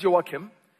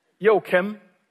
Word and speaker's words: Joakim: Well, as you heard, my name is Joakim, and I'm fully aware that that's a very hard name to pronounce in English. So, but let me just give you an idea. Imagine a rapper would greet the North Korean dictator, Joakim. That will get you in the vicinity of it.